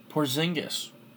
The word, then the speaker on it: Porzingis: The Knicks, Porzingis.